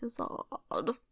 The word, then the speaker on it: Izzard: It's not Izzard like Gizzard. It's Izzard.